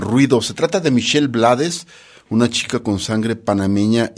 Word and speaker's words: Blades: Por cierto que el tío Rubén, a su apellido lo pronuncia Blades, Rubén Blades, y no es porque haya vivido muchos años en Nueva York,